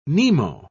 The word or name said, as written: nemo